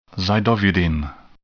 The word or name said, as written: zidovudine